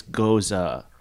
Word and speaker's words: Gose: It's pronounced much the same way the name Rosa, Goza, right? Yeah. Same way, with the emphasis on the O and the E making an sound. – Like H. Gose. – Because it's German, right? Yeah. So it's Gose.